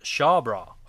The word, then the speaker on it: Chabrol: Claude Chabrol, I think it's pronounced Shawbra.